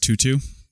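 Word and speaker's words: 2/2: The body section of the tunic is woven and is what's called a tutu, and that's two, looks like two divided by two, but it's pronounced tutu, diamond twill.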